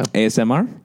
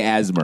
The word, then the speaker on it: ASMR: ASMR? – Yeah, yeah. – I think you say the letters. You don't say asthma.